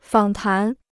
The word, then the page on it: 访谈: fǎng tán